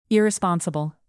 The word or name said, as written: irresponsible